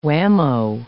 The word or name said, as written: whammo